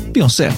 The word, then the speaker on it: Beyoncé: Beyoncé. – Beyoncé.